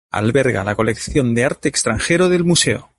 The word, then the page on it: extranjero: ispanskii